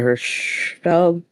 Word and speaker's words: Hirschfeld: The characters in this book, the primary character, Bertie, is a trans man who works for the Institute, is assistant to Dr. Hirschfeld. And I'm good. Am I saying that right? I apologize. Oh, yep. Dr. Magnus Hirschfeld.